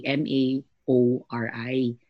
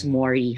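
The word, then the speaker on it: Māori: Um, actually, they, when we read it as Filipinos, we say, we say it as Maori, M-A, o R I. Maori. But it's Mori, how they really pronounce it.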